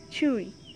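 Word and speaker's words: Chui (吹): Chui, pronounced like chew, with a forceful exhale, which is associated with the kidneys.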